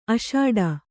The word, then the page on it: ಅಷಾಡ: aṣāḍha